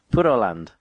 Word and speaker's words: Puroland: I mean, it's P-U-R-O, but Puro. – Puro. – Puro. You just have to pronounce the R as a bit of a mix with an L. Puroland.